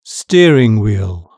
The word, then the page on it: steering wheel: British English